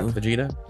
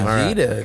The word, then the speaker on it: Vegeta: Did I pronounce that right? Vegeta. – Very nice. – Vegeta. Right. That's right.